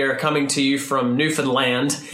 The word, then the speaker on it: Newfoundland: Newfoundland. – We've learned you gotta pronounce the land. – Yeah, emphasize the land.